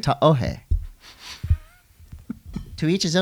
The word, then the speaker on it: Tahoe: I call it Ta'ohe. You can call it Tahoe, but if you want to sound like you know what you're doing, Ta'ohe.